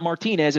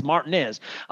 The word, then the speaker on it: Martinez: It's not Martinez. It's Martinez, and Evans.